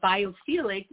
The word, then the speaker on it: biophilic: biophilic. Is that how you pronounce it? Biophilic? – Philia. Philia. We are, is the general term. And biophilic is, you know, we are as individuals biophilic people.